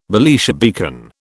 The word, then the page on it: Belisha beacon: British English